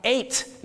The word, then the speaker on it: eight: ส่วนเบอร์8 อย่าลืมนะครับต้องออกเสียงทอทหารให้ชัดเจนที่ตัวสะกดนะครับ eight นะครับ eight